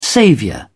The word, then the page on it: saviour: British English